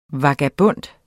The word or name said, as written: vagabond